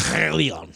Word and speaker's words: Chilion: And their two kids, Mahlon and Chilion. – Say that again. – Chilion. It's actually, that's kind of Hebrew. You got to clear your throat whenever you say that.